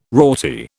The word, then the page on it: rorty: British English